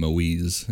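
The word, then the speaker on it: Moïse: Which they called him Moise, is the French Canadian pronunciation.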